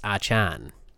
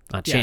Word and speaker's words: Achane: We did, and he apparently, his pronunciation, as we are big on pronunciations, it's Achan. – Achan. Yeah, sorry, Devon Achan.